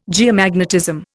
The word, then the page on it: geomagnetism: Indian